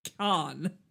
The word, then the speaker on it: Cannes: A film premiered at Cannes. Cannes? How do you say that? – Cannes.